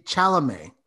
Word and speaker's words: Chalamet: Timothy, whoever Timothy this kid Chalamet. Is. – Yes. Like I said, he's. – I don't think you're saying it right. I don't think it's Chalamet. It's French. Chalamet? Yeah, you're definitely saying it wrong.